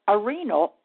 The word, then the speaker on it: Arenal: But this volcano is a pyramid, and it's called arenal, or the way they pronounce it is arenal.